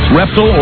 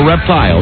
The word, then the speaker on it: reptile: Reptile or reptile? – Either one is acceptable. – Yeah, I figured there would be two pronunciations. – There would probably be the young, modern, contemporary pronunciation, reptile, and then the colonial version.